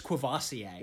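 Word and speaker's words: Courvoisier: Dad's saying it's Quavassier. – Yeah, that's what it is. – Shit. – Corvassier? – Quavassier. – It is Quavassier.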